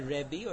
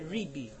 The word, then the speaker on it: Ribi: By the way, Ashkenazim will say Rebbe or Rabbi, and Sephardim will say Ribi, with a chirik under the resh.